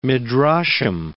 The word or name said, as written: midrashim